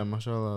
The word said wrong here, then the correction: Mahershala: Ali. – Is he playing Blade? – Mahershala. – Mahershala Ali. I never know how to pronounce his name.